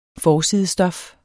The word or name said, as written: forsidestof